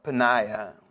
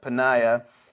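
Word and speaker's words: Peninnah: And then verse 4, and whenever the time came for Elkanah to make an offering, he would give portions to Peniah, his Peniah, maybe is probably a better way to pronounce that, Peniah, his wife, and all her sons and daughters.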